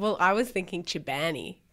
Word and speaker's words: Chobani: You should have gone first so I could have asked how to pronounce Chobani. – Chobani, how do you pronounce Chobani? – Oh, I don't know.